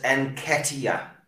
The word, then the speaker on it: Nketiah: Omar says Nketiah. That's how you say it.